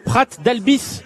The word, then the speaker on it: Prat d'Albis: Prat d'Albis, c'est bien comme ça qu'on dit, c'est bien comme ça qu'il faut prononcer, euh, Fabrice Rigobert. Oui, nous sommes partis sur cette prononciation, effectivement.